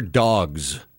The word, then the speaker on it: Dawgs: This is where Dawgs comes in. D-A-W-G-S, Dawgs, is how you say it. It's how you spell it, too.